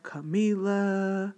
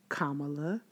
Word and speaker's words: Kamala: I know too many people that call her Kamala, Kamala. It's Kamala. Emphasis on the first syllable, Kamala, not anything else.